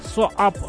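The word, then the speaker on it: SOAP: Короче, Дашка мне написала так: читается как соап, расшифровывается как сукин сын.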